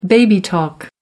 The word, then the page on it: baby talk: American English